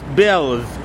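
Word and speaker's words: Bălți: Now, this city is spelled B-A-L-T-I, but it's really pronounced Bells, B-E-L-S, even though it's spelled B-A-L-T-I.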